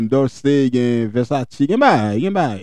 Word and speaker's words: Versace: Regardez, prononciation sans rage, Versace. Moi, c'est un Versace.